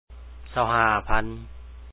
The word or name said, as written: 25000